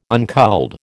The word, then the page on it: unculled: American English